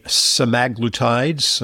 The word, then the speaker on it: semaglutides: We're going to talk about Ozempic and the semaglutides, that's the way they want us to pronounce it,